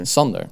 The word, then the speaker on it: Sander: Sander or Sander? – Sander. – What are we going to do? – It doesn't matter if you pronounce it kind of Englishy, then it's going to be Sander.